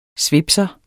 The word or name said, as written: svipser